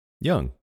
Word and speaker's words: Jung: How do you say it? – Jung. – Okay, so I was right.